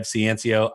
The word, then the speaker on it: Ciancio: The best way to find me is to search my name, Rev Ciancio. I'm sure nobody knows how to spell that. It is C-I-A-N-C-I-O.